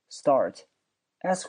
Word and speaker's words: start: 因为 s 后面加一个辅音，这个辅音都读它的浊辅音。 start，s 后面加 t 要读成 d。